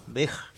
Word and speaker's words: Veja: Veja.